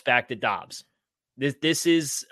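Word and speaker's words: Doubs: It's Dobbs, not Dubs.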